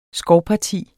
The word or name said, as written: skovparti